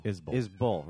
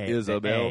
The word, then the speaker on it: Isbell: No, Isbell. Right. I always mess that up. I always say Bell. – As long as you don't do add the extra syllable, the Isabel.